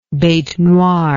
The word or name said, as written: bete noire